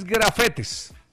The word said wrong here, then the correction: gafete: Ah, ok. – Es gafete. – Válido. No lo entendía, no lo pronuncié bien, tiene toda la razón.